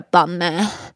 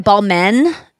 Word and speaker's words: Balmain: I think you're supposed to say it like a Balmain. And Balmain. And it was like tweed.